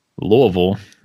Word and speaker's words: Louisville: We have Josh Thompson, who is currently the director of football operations at Louisville. Am I saying that correctly? I know there's... – You got that correct.